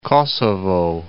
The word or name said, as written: Kosovo